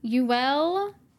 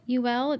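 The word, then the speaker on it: Youell: Uel, I think it's how you say it. Uel, it's Y-O-U-E-L-L, Uel, Swinney, I'm going with it.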